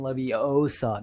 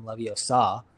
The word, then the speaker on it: Leviosa: She said, so in the movie, she goes, it's pronounced Wingardium Leviosa, not Wingardium Leviosa, right? – Oh, yeah, I remember that line.